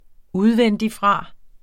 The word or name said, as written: udvendigfra